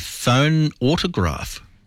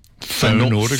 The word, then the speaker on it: phonautograph: He is also known to have invented the phone autograph. – Phonautograph.